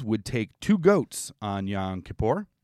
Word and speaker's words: Yom Kippur: How do I pronounce that properly? – Yom Kippur. – Yom Kippur. – Or Yom Kippur. – Okay. – Either one, both.